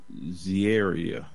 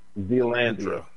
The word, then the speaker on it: Zealandia: It is called Zieria. Did I say that right? – Zealandia.